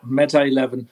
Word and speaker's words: Meta 11: Did I say that right? Meta 11? Is that how it's supposed to be like all together? – Yeah, Meta 11.